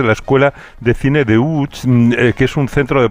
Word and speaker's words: Łódź: Aunque se pronuncia Łódź, se escribe Lodz, por si alguien lo busca Lodz, casi no tiene nada que ver la pronunciación.